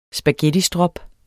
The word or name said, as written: spaghettistrop